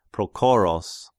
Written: Prochoros

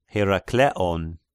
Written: Herakleon

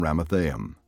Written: Ramathaim

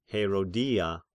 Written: Herodia

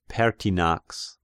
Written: Pertinax

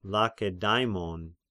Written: Lakedaimon

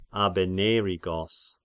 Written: Abennerigos

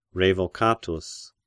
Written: Revocatus

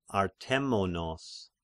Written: Artemonos

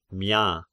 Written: Mia

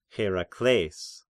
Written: Herakles

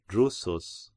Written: Drusus

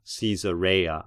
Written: Caesarea

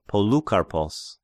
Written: Polykarpos

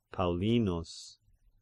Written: Paulinus

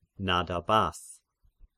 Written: Nadabath

